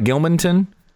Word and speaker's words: Gilmanton: Gilmanton, New Hampshire. Did I say that right? Gilmanton? – That's correct, yeah.